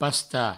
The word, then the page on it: pastar: pasta